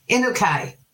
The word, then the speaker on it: Inukai: Inukai.